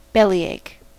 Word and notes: bellyache: American English

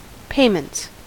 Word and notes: payments: American English

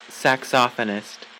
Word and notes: saxophonist: American English

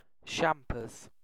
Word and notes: champers: British English

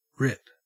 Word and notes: rip: Australian English

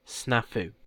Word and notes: snafu: British English

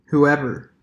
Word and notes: whoever: American English

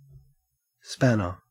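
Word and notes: spanner: au Australian

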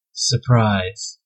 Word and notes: surprise: American English

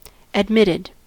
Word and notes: admitted: American English

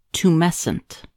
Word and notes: tumescent: American English

